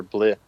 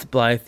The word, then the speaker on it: Blythe: We always have to cross the border into Blythe or Blith or whatever it is. – It's Blythe. You pronounce a lot of things wrong, man.